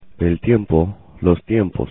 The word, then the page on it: tiempo: spanish